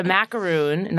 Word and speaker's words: macaroon: It's a macaroon, not a macaron.